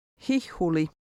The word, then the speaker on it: hihuli: Number 14 contains a double H, both of which are pronounced. Listen and repeat number 14. – Hihuli.